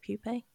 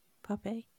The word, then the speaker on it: pupae: It's like they boil silkworm pupae. Is that how you say it? Pupae. Yeah. Pupae.